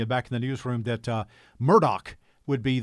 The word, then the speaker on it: Murdaugh: If you talk to those from that area, which I do, his name is pronounced Murdaugh.